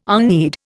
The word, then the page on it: unread: Indian